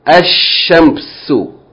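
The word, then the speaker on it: ash-shams: For example, Al Shams, the sun, is pronounced Ash-Shamsu. No change takes place in writing. You still write Alif Lam, but you're not going to pronounce the Lam.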